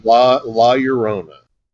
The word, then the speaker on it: La Llorona: Is La Llorona, am I saying that right, by the way? – La Llorona.